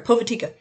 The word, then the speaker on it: Povitica: Um, we got um, Povitica. Uh, no, I didn't say that right. Hang on. Pov- no, I did say that right. Povitica. Um,